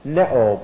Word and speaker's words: pneo: By the way, the root of that is pneuo, as you have it in your class notes, P-N-E-O, neo, P-N-E-O, P-N-E, long O on the end. That's the root form of it. And neo means to breathe. The P is silent.